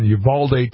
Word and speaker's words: Uvalde: And it was about the heartbreaking shooting that happened down at that Robb Elementary School in Uvalde, Texas. Did I say it right there? – Yes.